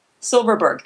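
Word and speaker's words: Silfverberg: Silverberg, the F is silent.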